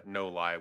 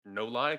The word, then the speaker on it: No-Li: They couldn't come up with a licensing agreement, so that's when they changed to No Lie, which is how it's easy to remember to pronounce No Lie, because it's Northern Lights. – Yeah. – It's not a Nolly, a Noli, whatever.